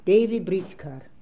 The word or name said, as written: Daivibritzkar